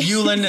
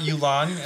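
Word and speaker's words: Yulan: And it's Yulin and not Yulon. And I will take anybody to task. It is Yulin.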